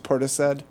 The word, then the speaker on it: Portishead: But it's pronounced Portishead. – Portishead, sure. – With an SH.